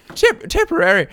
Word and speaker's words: Tipperary: Tipperary, am I saying that right? – You are. – Tipperary.